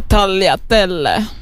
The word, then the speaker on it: tagliatelle: Alltså det heter faktiskt tagliatelle. – Men det är med G. Oh ju. God. Det är ju med G. – Ja, men G uttalas inte i italienska där jag kommer ifrån. Så tagliatelle. Anyway.